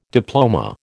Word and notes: diploma: American English

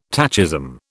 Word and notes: tachism: British English

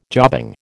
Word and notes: jobbing: American English

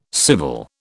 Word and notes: civil: British English

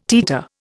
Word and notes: teeter: Indian

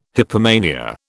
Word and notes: hypomania: British English

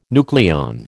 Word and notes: nucleon: American English

